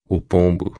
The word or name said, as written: pombo